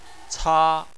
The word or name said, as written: ca1